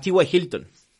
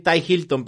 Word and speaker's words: T.Y. Hilton: Otro receptor que hay que tener en la mira es a, a T.Y. Hilton. Ya le iba a decir Ty Hilton, pero no era Ty Johnson y T.Y. Hilton. se escribe igual, pero se pronuncia diferente.